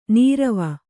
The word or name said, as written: ನೀರ್ವೂ